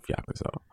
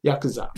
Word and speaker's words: Yakuza: Like some of the side quests are like straight out of Yakuza. Yeah, am I saying that right? I'm not saying that right. – Yakuza.